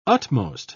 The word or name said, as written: utmost